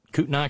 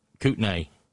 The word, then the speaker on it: Kootenai: Up in Kootenai County? Said that right, didn't I? Or is it Kootenai? Kootenai? Oh, potato, potato.